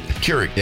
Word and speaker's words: Keurig: Johnny brought in a coffee maker because Krieg. – Krieg. – Keurig. – Yeah. Keurig. Keurig.